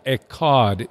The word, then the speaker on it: echad: I present the God of the Bible, who is the Father, the Son, and the Holy Spirit, and Ikad. The Shema, Deuteronomy 6:4. Hear, O Israel, the Lord our God, the Lord is one. That word one in Hebrew is, we pronounce it Ekad, E-C-H-A-D.